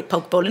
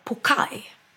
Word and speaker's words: poke: Men då uttalar jag den helt fel. Pokeball. För den uttalas pokai. – Va? – Ja. – Pokai bowl. – Hur konstigt. Stavas poke bowl med W. Och uttalas pokai.